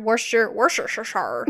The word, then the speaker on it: Worcestershire: But yeah, some of the ingredients, so it had spinach, zucchini, garlic, rosemary, Dijon mustard, Worcestershire sauce.